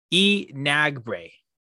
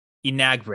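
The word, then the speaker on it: Enagbare: It's pronounced Enagbre. Enagbre. – That sounds good.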